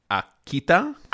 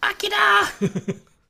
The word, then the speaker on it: Akita: Akita. – Akita!